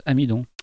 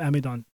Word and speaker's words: Amidon: Ça se prononce Amidon. – En français, oui. – Maiden. Maiden, c'est un américain. – Comment tu dis en anglais? – C'est pas loin, c'est Sam Amidon.